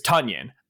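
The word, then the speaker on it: Tonyan: So it's Tunyon. Funyon. Yes, I always said it wrong.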